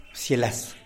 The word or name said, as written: psialas